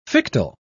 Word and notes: fictile: British English

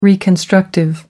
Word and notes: reconstructive: American English

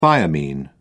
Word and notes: thiamine: British English

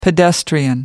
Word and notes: pedestrian: American English

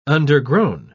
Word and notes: undergrown: British English

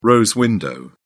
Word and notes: rose-window: British English

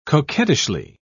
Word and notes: coquettishly: British English

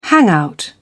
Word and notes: hangout: British English

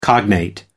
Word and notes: cognate: American English

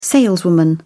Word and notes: saleswoman: British English

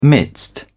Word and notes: midst: British English

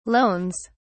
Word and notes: loans: British English

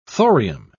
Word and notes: thorium: British English

